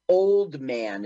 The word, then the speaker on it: Oldman: And Gary, is it Ullman? Yeah, it's Gary Ullman, right? It's not Gary Sinise. He's to no, but Gary Oldman is what the internet told me. I think it's spelled wrong. – Oh. – How do oh. you say it? Oldman, I would always. Oldman. Oldman. Gary Oldman. Yeah, Gary Oldman is the ooh shady,